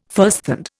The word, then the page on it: firsthand: Indian